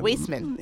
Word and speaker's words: Waisman: And so go to the Weissman Center, and that's spelled W-A-I-S-M-A-N. Mm-hmm. So Weissman or Weissman? – Weissman.